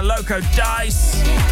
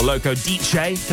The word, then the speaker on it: Loco Dice: Loco Dice, or Loco DJ, depending on where you're from, how you say it.